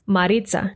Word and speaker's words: Maritza: And actually, the pronunciation changes according to the accent. For example, my husband and his family pronounce my name with their Colombian accents, Maritza.